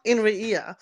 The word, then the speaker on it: Inria: And so the character who is considered like an alien explained that in their language, it is kind of pronounced weird. It's pronounced Inria.